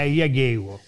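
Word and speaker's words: Jagiełło: Lui, lui, sai che Matteo eh, Spellat l'altra volta ci ha spiegato come, perché lui vive in Polonia, ci ha spiegato come si pronuncia Iagello è Iagewo.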